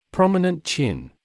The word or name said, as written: prominent chin